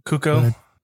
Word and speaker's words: Cuoco: And I've heard a lot of praise, but it's just one of those things where I'm just like, really, Haley Cuoco or Quoke? I don't know how to say her name. – Cuoco. Yeah.